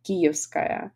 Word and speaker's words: Kyivskaya: And this is my real surname, and I'm originally from Kharkiv, and you know, my surname is spelled Kuyevskaya, Kyiv. It is, but I'm writing Kyiv, Kyivskaya. It is not my mistake, it is just I want to make not Kuyevskaya, but Kyivskaya. all my relatives are in social media, like Kuivskaya, Kuivski, but I'm Kyivskaya.